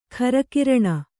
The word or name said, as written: ಖರಕಿರಣ